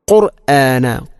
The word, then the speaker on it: القرآن: ഇവിടെ ഖുർആന എന്ന പദത്തിൽ പ്രത്യേകിച്ച് നമ്മൾ ശ്രദ്ധിക്കണത്, എപ്പോഴും നമ്മൾ പറയുന്നൊരു വാക്കാണത് ഖുർആൻ. നമ്മൾ പലപ്പോഴും ഖുർആൻ ഖുർആൻ എന്നാണ് പറയുക. അപ്പോൾ ഖുർആന ഖുർആന, ഖാഫിൻ്റെ ഉച്ചാരണമെങ്കിലും പ്രത്യേകിച്ചും ശ്രദ്ധിക്കണം, ഒരിക്കലും കാഫിനെ കൊണ്ട് പറയരുത്.